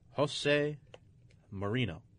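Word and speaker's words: José Mourinho: He picked Manchester United because they finally fired their manager, Jose Mourinho. – Yes. – I think that's how you pronounce his name. I'm probably totally wrong. – No, you're correct.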